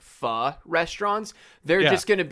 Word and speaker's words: pho: Not called Thai food near me, but it's called P-H-O. How do you pronounce that dish? – Pho. – That's what I thought. That's the way I've always said it. And this place is called P-H-O-K-I-M.